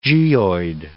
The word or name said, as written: geoid